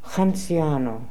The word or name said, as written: Hanziano